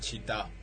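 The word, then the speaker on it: città: a ver, chita, que es ciudad, se escribe cita, pero es chita.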